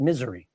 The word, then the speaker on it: Missouri: And I ended up going to a place called Springfield, Missouri. We pronounced it Misery.